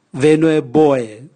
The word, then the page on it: venue boe: Raga